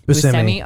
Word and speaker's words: Buscemi: How do you actually say his damn name? – Buscemi. – Okay, yeah. I always say it wrong then.